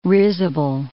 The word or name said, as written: risible